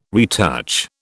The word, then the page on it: retouch: British English